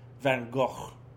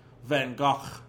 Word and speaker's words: Van Gogh: And with a little. Ch, ch, ch, like, yeah, phlegm in your throat. Van Gogh.